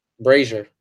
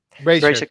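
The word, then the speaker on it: Brasier: Eh, otra noticia, Ryan Brazier, Brasil, ¿cómo se pronuncia? Brazier, ¿cómo es? – Brazier. Brazier, gracias.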